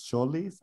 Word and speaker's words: Tzolis: His name is Christos Tzolis, like T Z O L.